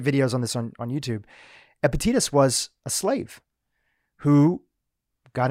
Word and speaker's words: Epictetus: And this individual, I always mess up the pronunciation of the name, but Epictetus? – Epictetus. – Epictetus.